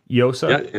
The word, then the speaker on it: Llosa: Llosa? – Yeah, but unlike llama, the 2 L's is a Y sound.